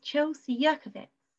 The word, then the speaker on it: Chelsea Jurkiewicz: And with me is Chelsea Jurkiewicz.